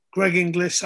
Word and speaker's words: Greg Inglis: Because we spoke earlier about Greg Inglis.